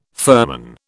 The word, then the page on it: firman: British English